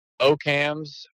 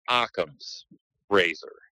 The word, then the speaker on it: Occam's razor: I used to say Ocams, or, you know, that's basically how I pronounced it, but I was told numerous times it's pronounced Occam's razor.